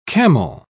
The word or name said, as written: camel